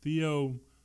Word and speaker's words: theopneustos: In Greek, that's theopneustos. Theopneustos. I know the P is pronounced.